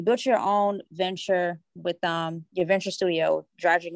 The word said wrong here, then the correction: Dyrdek: Machine. Is that, am I correct? – Dyrdek, like my last name. – Dyrdek Machine.